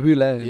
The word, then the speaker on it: Wu Lei: Eh, Wei Lu of Lu Wei, hoe moet je het uitspreken? Wu Lei.